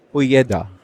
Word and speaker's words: Ojeda: Miguel Oida? Miguel Ojeda.